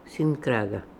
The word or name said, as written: Sinkraga